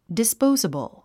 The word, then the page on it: disposable: dispóuzəbl; ディスポウザブル